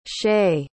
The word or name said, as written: shaye